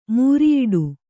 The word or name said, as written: ಮೂರಿಯಿಡು